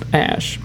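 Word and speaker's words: Æ: And then the AE is pronounced Ash.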